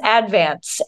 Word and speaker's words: Advance: It's spelled advance, pronounced advance. And if you pronounce it advance, people are really mean to you about it.